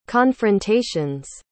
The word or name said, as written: confrontation's